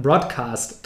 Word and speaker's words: Broadcast: Und dann haben wir uns gedacht, machen wir den Broadcast draus mit Betonung auf Bro.